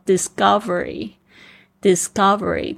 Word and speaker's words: discovery: discovery，discovery，特别是那个 c 的发音呢，本来是。的声音，但是在这边呢，因为它前面碰到了这个 s 的轻音，所以它变成 g 的声音。